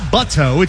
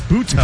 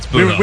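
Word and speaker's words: Butto: It's not Buto, it's Buto. – That's right, it's Buto. – We were kind of going over how to pronounce his name, and luckily we were told that it was Buto.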